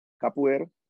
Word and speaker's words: capoeira: Capoeira.